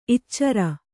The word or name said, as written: ಇಚ್ಚಾರಿ